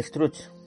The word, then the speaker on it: Struch: El conde Struch, pronunciado como Struch, fue, según una leyenda catalana, un noble catalán del siglo XII llamado Guifred.